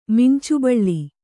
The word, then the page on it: ಮಿಂಚುಬಳ್ಳಿ: kannada